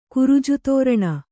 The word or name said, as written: ಕುರುಜುತೋರಣ